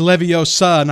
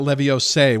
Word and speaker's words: Leviosa: And they gave me the magic, you know, Expelliarmus, you know, not Expelliarmus, what was it, like the from Harry Potter, it's Leviosa, not Leviose.